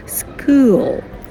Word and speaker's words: school: Смотрите, когда вы произносите школу, помните, эска, она забирает аспирацию звука к, и он не аспирируется. То есть school, yeah?